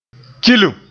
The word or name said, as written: kilim